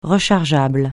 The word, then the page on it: rechargeable: francais